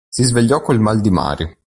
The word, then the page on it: mare: italian